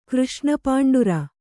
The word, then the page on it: ಕೃಷ್ಣಪಾಂಡುರ: Kannada